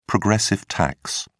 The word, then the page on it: progressive tax: British English